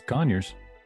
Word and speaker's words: Conyers: Atlanta Pizza in Euro in Conyers, Georgia. Did I say that right? Conyers, Georgia? – Yeah. Oh, it's Conyers.